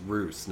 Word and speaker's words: Roose: I get it mixed up because it's It's Roose, not Russ, right? So yeah, I start. Roose. Okay. – Roose and Ramses. I guess Roose probably like a bastardization of Russ or Russell. – Isn't it spelled like noose but with an R? – R O O S E.